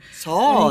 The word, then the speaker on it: Seoul: Schreibt man das mit E, O. Und das irritiert mich immer total. Ich finde das ganz komisch. Naja. Mhm. Aber ja, es ist Soul.